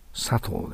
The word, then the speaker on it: subtle: Subtle って書いてあるけども、B を発音しません。ね、 Subtle です。